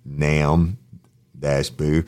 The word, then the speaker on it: Nambu: Gino G, Nambu, and the behind the Gino G is spelled G-Y-E-N-O-N-G-G-I. He puts in parentheses G-G-on-G, close parentheses, Nambu, and he put Nam-boo.